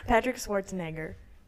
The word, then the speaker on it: Schwarzenegger: Patrick Schwarzenegger. Did I say it right? – Yeah.